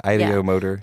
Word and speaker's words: ideomotor: But that also opens up the chance for that, how do you say it, ideomotor? – Yeah, ideomotor. Yeah.